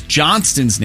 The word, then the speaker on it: Johnston: Thanks to him, I know the T in Ross Johnston's name is silent.